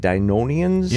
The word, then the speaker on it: Dynonian: Dynonians? – Yeah, Dynonian seems to be the preferred pronunciation there.